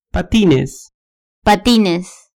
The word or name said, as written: patines